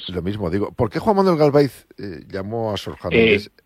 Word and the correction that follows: Galaviz: Es Juan Manuel Galaviz Herrera. – Disculpa, Galaviz. Digo la pronunciación.